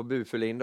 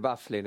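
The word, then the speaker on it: Byfuglien: Skönt att du kör det norska uttalet där också på Bufulin då, inte Bafflin utan eh, ja, det, det, Nej, det är väl lite norskt?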